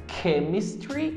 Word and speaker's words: chemistry: A primeira é K, como por exemplo nas palavras chemistry e character.